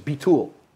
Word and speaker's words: bitul: And, you know, with that pronunciation, bitul. I say bitul with Ashkenazic accent. She said, bitul. I said, oh, yeah, bitul. She says, no, bitul. I'm like, yeah, bitul, right? She's pronouncing with the dagesh on the second syllable. And I was saying, bitul. I said, yeah, bitul, okay, bitul. She says, no, bitul, be a tool of Hashem.